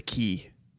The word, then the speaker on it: quay: C-A-Y? – Do you pronounce that K? – Well, you pronounce Q-U-A-Y, key. – I kind of just assumed everything... – It's a good everything... – Scrabble word. – Okay. – Yeah. – Yeah. Australians pronounce it key. – It's tough to say, Tanner. – Do us Americans and you Brits? – We pronounce Q-U-A-Y, key, certainly.